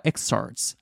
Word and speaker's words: excerpts: この excerpts、まあ、文字にしたら excerpts R と T の間に P があるんだけれども、まあ、言ったら exorts。まあ、 P は発音しないですね。